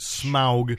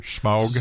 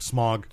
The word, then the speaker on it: Smaug: And then when smog is... – Let's pronounce it differently. Smaug. – Smaug. – So that it's not smog. – Smaug. – S-M-O-G.